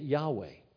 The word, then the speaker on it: Yahweh: And they took the word Adonai and took the vowels and put it in there, and that's where we get Yahweh.